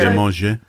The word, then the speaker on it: Prêt à manger: It's manger. Manger. – Manger. – But I think yours is better. – Prêt à manger.